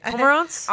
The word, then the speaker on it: cormorant: Cormorants? – Honestly, right, I was cormorant. Like, amaranth.